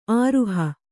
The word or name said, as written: ಆರುಹ